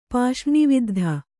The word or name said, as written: ಪಾಷ್ರ್ಣಿವಿದ್ಧ